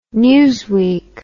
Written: Newsweek

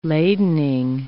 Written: ladening

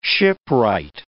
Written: shipwright

